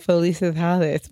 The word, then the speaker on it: felicidades: Felicidade is one. Why can't I pronounce it? Felicitar is the verb, but felicidades. That's the verb, but I need the, oh God, kill me. Anyway, Hold on. Felicitar. – Felicidades. – Felicidades.